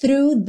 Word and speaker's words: through: Crash through the surface. Crash through. Through, through, fa-fa-fa, linguinha lá no dente da frente, e já puxa no R, through, through, o GH não é pronunciado.